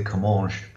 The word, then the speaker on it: Comminges: 47 games in all competitions, no goals, and I always struggle to pronounce his name, but I'm going to go for it as I always do. It's Miguel Comminges, or Comminges. – Well, I'm going with Comminges because Guadeloupe is a French territory, and if they're saying his name in French, they would say Comminges.